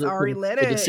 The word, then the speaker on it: Ari Lennox: Shout out to Ari Lennox. Is that how you say her name? Ari Lennox. – Yes, Ari Lennox.